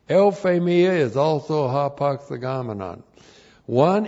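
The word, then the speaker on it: Euphemia (εὐφημία): euphemia. And we've had deuthphamia, now the other word, which is also a hapax legomenon. Euphemia. E-U-P-H, long E-M-I-A.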